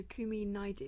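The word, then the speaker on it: Kumi Naidoo: Tomorrow night there will be a talk by Dr. Kumi Naidu. Is that how you pronounce that? Yep, that's right.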